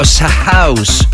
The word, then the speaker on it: SHOUSE: Te cuento, en 2017, finales, un dúo australiano, Ed y Jack, ellos artísticamente se llaman House o S-House, o S-House, o S-House. En todo caso se escribe S-H-O-U-S-E. Es decir, delante de la palabra house colocaron una S.